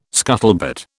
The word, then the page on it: scuttlebutt: British English